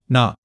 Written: ne5

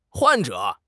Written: 患者

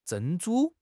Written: 珍珠

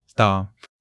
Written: de5

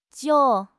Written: jiu3

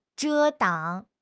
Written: 遮挡

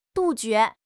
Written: 杜绝

